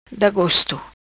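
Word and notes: Gosto Da: patua

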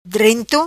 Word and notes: Drento: Patua

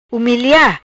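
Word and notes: Humilia: patua

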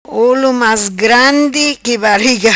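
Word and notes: Olo Mas Grandi Qui Bariga: patua

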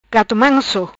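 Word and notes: Gato Manso: patua

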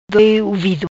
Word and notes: Doi Uvido: patua

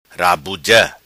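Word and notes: Rabuja: patua

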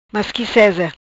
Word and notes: Masqui seza: patua

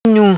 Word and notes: Nhum: patua

